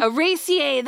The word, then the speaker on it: Araceae: This one's Araceae. – Say it again. – How a boy said it. Araceae. – Araceae. That's how you say it.